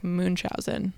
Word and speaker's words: Munchausen: That's how I thought it was pronounced, but then I Googled it to make sure I was going to say it right here. And on YouTube, on like the pronunciation, and also on Google Translate on the pronunciation, they say it like Munchausen.